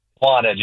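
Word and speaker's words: Wanaque: So it's pronounced Wanage? – Wanage, yeah, yeah.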